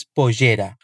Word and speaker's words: pollera: Em algumas regiões do Uruguai, a pronúncia do Y e do LL tem um som parecido com o J. Por isso, Martin diz pollera.